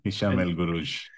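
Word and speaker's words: Hicham El Guerrouj: Hisham El Guruj. – That's how you pronounce it.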